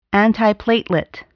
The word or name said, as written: antiplatelet